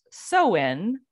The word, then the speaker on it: Samhain: Even though it's pronounced Samhain or Samhain, S-O-W, that's the sound. Mm-hmm. Would that be so or sow? – Probably sow. – Sow, Samhain. Okay. But it's spelled S-A-M, like Sam, and then H-A-I-N.